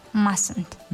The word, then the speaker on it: mustn't: Mustn't. – نوشته می شود و هنگامی در شکل مختصر تلفظ کردنی آن یکی از حرفای آن یکی از حرفی آن یعنی حرف ت تلفظ نمی شود.